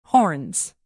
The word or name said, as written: horns